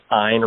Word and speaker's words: Ayn: Like line, it's Ayn Rand.